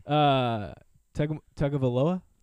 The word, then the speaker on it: Tagovailoa: Yeah, this is funny. Tagovailoa? – Yes. – Did I say it right? – Yes.